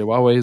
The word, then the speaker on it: Huawei: Huawei hatte eine Zeit, also Huawei, äh, richtig ausgesprochen wird es ja Huawei.